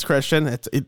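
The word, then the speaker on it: Kristian: It's Christian. He's Finnish, but they still say it like Christian.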